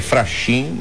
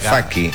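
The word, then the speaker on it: Fachin: A gente lê Faxin, mas se pronuncia Faquin.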